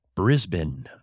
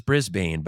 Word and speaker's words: Brisbane: I learned how to pronounce Brisbane. I thought it was Brisbane, but apparently that's not how you pronounce it.